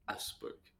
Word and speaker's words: Augsburg: How do you say it? Augsburg? Am I saying it right? Or I think they just say Augsburg. – Augsburg.